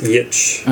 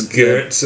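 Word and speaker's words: Giertz: It starts with a G. – Yeah, it's pronounced Yitch. – Okay, I believe. That's why I didn't. – I think I'm saying it right. First, so. Yeah, everyone thinks Gertz. Everyone thinks Gertz or something like that.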